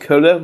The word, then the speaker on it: Kölle: Das ist Kölle, so spricht man das auch aus.